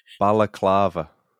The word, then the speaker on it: balaclava: So she sees a man standing right outside the window with a baklava. Is that how you say it? No, baklava's candy. – Yeah, that's okay, isn't it? – Baklava. Fuck, how do you say it? – Balaclava.